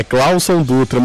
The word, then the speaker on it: Cláudio Dutra: Ai, gente, olha, a gente tá rindo muito da maneira como vocês estão pronunciando o meu nome, tá? É Cláudio Dutra, mas eu nem julgo o Ricardo Garcia, porque é um nome complicado mesmo, né?